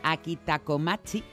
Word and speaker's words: akitakomachi: También los japoneses, la variedad más utilizada para hacer el sushi se llama akitakomachi, ahí lo he dicho bien, y esa también es de grano corto.